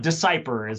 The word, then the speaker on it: DeCuypere: DeCuypere is sort of the way I guess my family has decided to say it in the Americas, I guess.